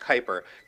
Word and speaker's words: Kuiper: The place where Pluto, the other dwarf planets, and a lot of other fun objects in our solar system orbit is called the Kuiper Belt. It's pronounced Kuiper, just like Mel from ESPN.